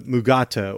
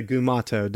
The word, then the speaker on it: Mugato: And let's face it, this episode gave us the Mugato or the Gumato, depends on which school you're from. Are you from Scripps School or are you from the DeForest Kelly mispronouncing it school? – I always go with DeForest Kelly.